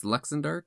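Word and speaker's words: Luxendarc: And the basic overview of Bravely Default is that you're in the world, I think it's pronounced Luxendark.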